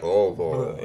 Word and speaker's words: Louisville: Well, you gotta let your tongue just like go dead when you say it, so it's like Louisville.